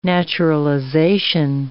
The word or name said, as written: naturalization